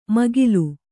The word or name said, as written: ಮೇಖಲೆ